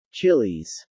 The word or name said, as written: chillis